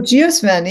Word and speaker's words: geosmin: And one of the ones was, and I don't even know if I know how to pronounce it, geosmin? – Oh, geosmin, yeah.